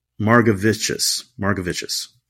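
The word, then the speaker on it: Margevicius: Yeah, according to Baseball Reference, you pronounce it Margavicious, with the emphasis on the Gav, G-A-V.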